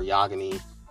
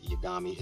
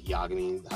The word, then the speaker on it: Yagami: And Death Note is, you know, the story of Light Yagami, or Yagami, however you want to pronounce it. I don't even know why I said it Yagami. It's Light Yagami, how they say it.